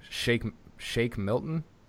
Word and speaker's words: Shake Milton: Shake Milton.